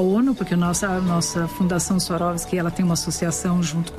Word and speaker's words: Swarovski: Ela fala Swarovski, isso é a pronúncia correta aí para quem quiser ser precioso.